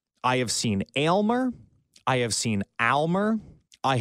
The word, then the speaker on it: Aylmer: But Weaver had a caller yesterday emphatically say it's pronounced Aylmer, like ale, the beer.